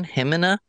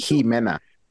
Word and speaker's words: Ximena: Yeah, it's with an X. – Ximena. – Yeah. – And pronounce it again. – Jimena. Like H-E, Jimena.